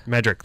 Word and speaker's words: Medrick: Just yesterday or the day before yesterday or yesterday, I had Alice Madrick. – Alice Medrick. – Medrick, thank you. Yes. I always say it wrong a little bit.